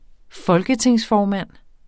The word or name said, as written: folketingsformand